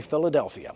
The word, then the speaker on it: Philadelphia: And that word for love is literally and pronounced exactly this way, Philadelphia.